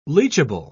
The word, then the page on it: leachable: British English